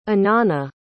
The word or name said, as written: anana